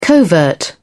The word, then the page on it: covert: British English